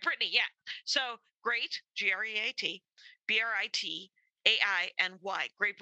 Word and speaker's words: Britainy: And her name is spelled like Britain, and it's Britainy.